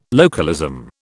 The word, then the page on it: localism: British English